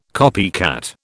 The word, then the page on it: copycat: British English